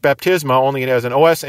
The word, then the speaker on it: baptisma: That is the basic verb, bapto, and that is spelled B-A-P-T-O. Related to bapto is the noun Baptisma. Spelled B-A-P-T-I-S-M-A, baptisma. And then we also have the word baptistes, B-A-P-T-I-S-T-E-S.